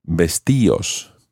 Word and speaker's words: vestíos: the D comes off and you have vestíos. However, you have to add an accent to the I in order to keep that type of pronunciation. Vestíos, vestíos.